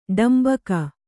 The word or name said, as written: ಡಾಂಬಿಕ